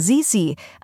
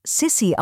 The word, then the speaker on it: Sisi: Man kennt sie vor allem unter ihrem Spitznamen Sisi, eine Kurzform von Elisabeth. Wegen berühmter Filme über ihr Leben wird der Name oft Sissi ausgesprochen. Das ist aber nicht richtig.